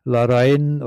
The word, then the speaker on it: Larrain: Larrain oder Sergio Larrain. Larrain schreibt man L-A-R-R-A-I-N.